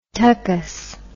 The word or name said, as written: tuckus